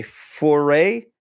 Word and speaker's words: foray: not four way foray is that how you say it? Foray yes, into yes, new gaming.